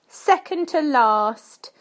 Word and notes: second to last: British English

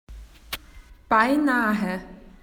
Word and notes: almost: Australian English

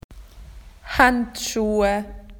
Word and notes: gloves: Australian English

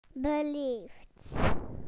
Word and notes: polite: British English

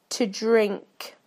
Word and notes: to drink: British English

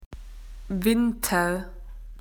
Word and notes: winter: Australian English

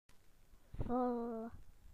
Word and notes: full: British English

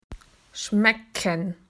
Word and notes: to taste: Australian English

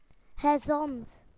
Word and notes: healthy: British English

